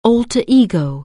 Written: alter ego

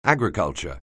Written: agriculture

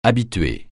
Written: habituer

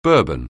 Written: Bourbon

